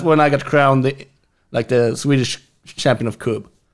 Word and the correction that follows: Kubb: Can you, listen, I've now pronounced it 4 different ways. Can you just spell the name of the game for us? – K-U-B-B. – Coop. Okay. All right.